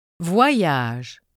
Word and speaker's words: voyage: OY is pronounced as Y. – Voyage. Moyen.